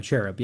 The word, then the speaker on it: cherub: And I don't even, is it like a cherub? Is that how you say it? Like, you know, like the little chubby baby? – Yeah, cherub. Yeah.